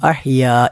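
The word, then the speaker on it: അഹ്യാ (أحيا): പ്രവാചകൻ സല്ലല്ലാഹു അലൈഹി വസല്ലമ ഉറങ്ങാൻ വേണ്ടി കിടപ്പിറയിലേക്ക് കിടന്നു കഴിഞ്ഞാൽ പ്രവാചകൻ സല്ലല്ലാഹു അലൈഹി വസല്ലം പ്രാർത്ഥിക്കാറുണ്ടായിരുന്ന ഒരു പ്രാർത്ഥനയായി പ്രവാചകൻ്റെ ഭാര്യമാർ പഠിപ്പിക്കുന്നത് ബിസ്മിക്കല്ലാഹുമ്മ അമൂതു വ അഹ്യാ എന്നാണ്. ബിസ്മിക്കല്ലാഹുമ്മ അമൂതു വ അഹ്യാ വളരെ എളുപ്പമുള്ള പ്രാർത്ഥനയാണ്. ഉച്ചാരണത്തിൽ അഹ്യാ എന്ന ഹാ മാത്രമാണ് ശ്രദ്ധിക്കേണ്ടതായ പ്രത്യേകമായ വാചകം.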